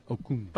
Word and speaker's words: Okung: Okung. – But it's still the G is still pronounced.